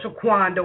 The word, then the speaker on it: Shaquanda: This your girl, Shaquanda. What's up? And just in case y'all want to hate on my name, it's spelled S-H-A-Quanda.